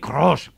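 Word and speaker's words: Kroos: hay que pronunciar bien, me lo ha dicho Miguel Gutiérrez, es Tony Cross, con una ola. Cross, Cross, Cross, cross, G incluso.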